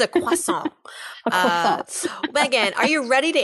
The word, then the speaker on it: croissant: She said it like that. Croissant. I don't pronounce things actually in the native tongue, even though I know very well that the French call it a croissant, but in New York, it's a croissant. I was really impressed that she was sticking to the French, the French pronunciation.